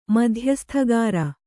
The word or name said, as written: ಮಧ್ಯಸ್ಥಗಾರ